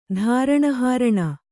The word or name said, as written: ಧಾರಣಹಾರಣ